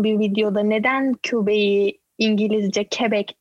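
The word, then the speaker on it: Québec: Quebec değil yalnız canım Quebec.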